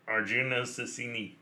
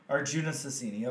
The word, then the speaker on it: Arjuna Susini: Arjuna Susini. – Yeah, Arjuna Susini, okay.